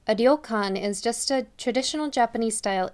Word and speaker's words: ryokan: As you may already know, instead of having direct R and L sounds in Japanese, they use a sound that's somewhere in between, as you can hear in ryokan.